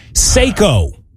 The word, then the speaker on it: Seiko: Seiko is how you pronounce it.